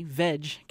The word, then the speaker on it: Vedge: That restaurant just closed this month, and just down the road on Main Street, a new place called Veg Cafe, and it's spelled V-E-D-G-E, Veg Cafe.